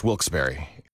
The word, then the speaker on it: Wilkes-Barre: The only way to pronounce Wilkes-Barre.